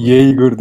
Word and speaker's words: Yeager: Ya Yeager özellikle baktım ya nasıl okunuyor. Yeager diye okunuyor.